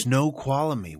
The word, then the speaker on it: Snoqualmie: Snoqualmie, I believe is how you say it. – Snoqualmie. Wow. – It's S-N-O-Q-U-A-L-M-I-E.